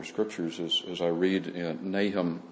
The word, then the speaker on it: Nahum: And so Nahum, some would pronounce it Nahum, possibly, is the prophet here.